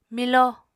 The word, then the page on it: milo: Apatani